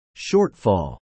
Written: shortfall

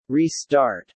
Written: Restart

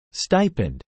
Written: stipend

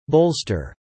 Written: bolster

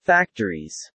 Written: factories

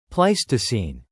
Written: Pleistocene

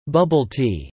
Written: Bubble tea

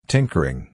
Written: tinkering

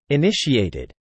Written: initiated

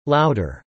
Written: louder

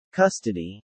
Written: custody

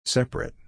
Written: separate

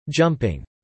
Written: jumping

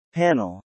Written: panel

